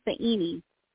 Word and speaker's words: Soyini: Yeah, it's confusing because it looks like S-O-Y, but it's really pronounced like Sa and then Eni, Soyini.